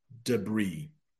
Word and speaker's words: debris: The next one is debris. That is how that is pronounced. I know there's an S at the end, but we pronounce it debris.